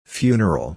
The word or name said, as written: funeral